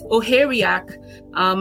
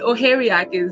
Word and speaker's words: Ohiaeriaku: And now my last name, it is an Igbo last name. I grew up a Yoruba person and I tend to put that Yoruba accent on everything. Mm-hmm. So, but Ohia-Riaku is how I pronounce it.